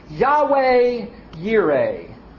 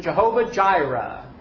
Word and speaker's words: Yahweh Yireh: Verse 14, "And Abraham called the name of that place Yahweh Yireh," or as we sometimes hear it in the Germanic pronunciation, Jehovah Jireh, right?